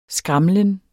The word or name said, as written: skramlen